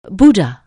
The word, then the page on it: bud: anglais